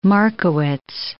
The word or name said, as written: Markowitz